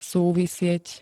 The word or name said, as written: súvisieť